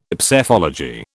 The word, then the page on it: Psephology: British English